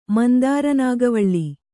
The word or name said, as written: ಮಂದಾರನಾಗವಳ್ಳಿ